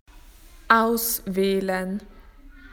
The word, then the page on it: to choose: Australian English